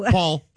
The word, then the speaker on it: Poll: P-O, it's pronounced Paul, there's a but it was spelled P-O-L-L, as in pole. – Mm. Paul, like a boating pole, yeah.